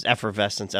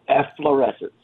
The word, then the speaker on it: efflorescence: I've heard it, people pronounce it multiple different ways, effervescence, efflorescence. I think we decided efflorescence was the pronunciation we were going to stick to. – Yes, that is the correct, efflorescence.